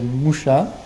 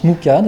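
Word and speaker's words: Mucha: Celui-ci, donc, c'est d'après Moucha, qui se prononce Mouka, d'ailleurs, qui est un artiste hongrois, donc du 19e, début du 20e, et qui a beaucoup représenté, donc, et qui a mis...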